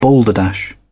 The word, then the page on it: balderdash: British English